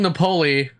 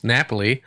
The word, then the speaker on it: Napoli: Oh, I kept calling him Napoli. I just realized that I'm a dumbass and it's actually pronounced Napoli.